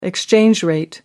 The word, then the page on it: exchange rate: American English